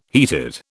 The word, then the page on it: heated: British English